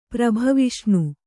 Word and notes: ಪ್ರಭವಿಷ್ಣು: prabhaviṣṇu

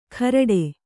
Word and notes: ಖರಡೆ: kharaḍe

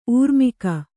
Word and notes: ಊರ್ಮಿಕೆ: Kannada

